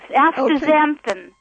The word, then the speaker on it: astaxanthin: have been taking the anthoxanthin and then also, did I say that right? – You're close. Astaxanthin.